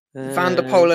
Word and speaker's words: Van der Poel: Van der Poel and Nadevejt going on the attack.